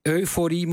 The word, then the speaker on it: euforie: Want inderdaad, de klemtoon ligt niet als euforie, maar euforie. Je zou nog euforie mogen zeggen. Mag allebei. – Dat mag allebei, ja. – Maar niet op fo. Dus bij deze euforie.